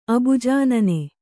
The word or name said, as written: ಅಬುಜಾನನೆ